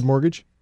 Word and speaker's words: mortgage: So say the word mortgage? – Mortgage. – Okay. – But there is a silent T in there.